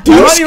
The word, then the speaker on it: Dushku: No, Dushku. Right. That's Dushku, how you say it.